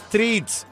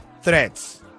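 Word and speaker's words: Threads: No es treats, es threats.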